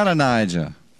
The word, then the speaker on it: Adonijah: Adonijah.